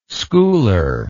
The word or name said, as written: schooler